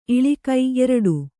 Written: ಇಳಿಕು

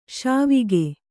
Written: ಶಿವಕ

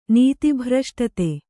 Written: ನೀತಿಭ್ರಷ್ಟತೆ

